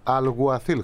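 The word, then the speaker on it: Alguacil: Δηλαδή και ο καινούργιος προπονητής φαίνεται ότι πρέπει να προφέρεται Αλγουαθίλ, χωρίς το ΚΑΠΑ, αλλά τέλος πάντων.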